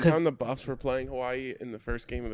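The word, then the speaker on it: Hawaii (Hawaiʻi): because you're seeing it more common now with the apostrophe between the two I's in mm-hmm. Hawaii, so you have to have that hard stop to say it right.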